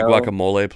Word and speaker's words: guacamole: Look, dude, if you're gonna make guacamole, are you gonna want the avocado? – Can you pronounce it guacamole, please? It's a mole. It's one of the moles.